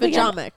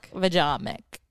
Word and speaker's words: Vagomach: Vajamic. – Vajamic. – V-A-G-O-M-A-C-H. – V-A-G-O-M-A-C-H. – Urban Dictionary? – Urban Dictionary was the first one that came out. – What's the definition? – Pronounced the ja mick.